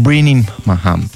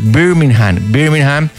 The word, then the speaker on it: Birmingham: Patrick Murray, cantante de reggae británico, nacido en Birmingham. A ver, no, lo estoy pronunciando mal. Es Birmingham, de Inglaterra.